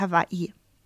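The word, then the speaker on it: Hawaii (Hawaiʻi): Also ich habe es jetzt auch nicht ganz korrekt ausgesprochen, aber es ist eigentlich Hawaii. – Hawaii. – Also es gibt ja dieses... umgekehrte Apostroph nach dem ersten I. Das ist ein Okina und es ist eigentlich auch sehr wichtig,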